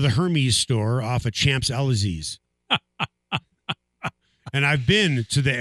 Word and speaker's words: Champs-Élysées: Champs-Élysées? Is that how you pronounce it? – Champs-Élysées? – Champs-Élysées? – Yes. I'm going to go shopping on the Champs-Élysées.